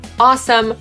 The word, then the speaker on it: awesome: Po amerykańsku wymawiamy to jako awesome, ale to już pewnie doskonale wiecie. Zwróćcie tylko uwagę, że na końcu nie jest some ani sam, tylko some. Awesome.